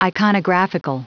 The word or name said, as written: iconographical